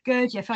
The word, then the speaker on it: Gurdjieff: Gurdjieff. – Gurdjieff.